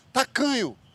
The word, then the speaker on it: tacanho: Agora, só te lembrando, Emicida, quando for usar de novo, tem que botar o sotaque baiano. Tacanho! É tacanho! E repetir duas vezes. – E você põe essa força. Essa força na sílaba do meio.